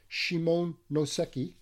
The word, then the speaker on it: Shimonoseki: However, within 1 week of the treaty with Japan, the Treaty of Shimonoseki, don't always pronounce that correctly, Shimonoseki.